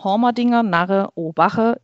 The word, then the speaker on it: D'Hoamerdenger Narra Obacha: So lange bin ich bei den Obachnen, also den richtig ausgesprochen, der Hormerdinger Narre Obache im TSV Heimerding.